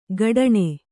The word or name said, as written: ಕಠಾಣೆ